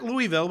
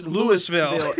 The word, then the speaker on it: Louisville: Not Louisville, but it's Louisville.